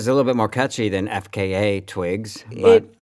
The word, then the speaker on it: FKA Twigs: Pronounced FKA Twigs.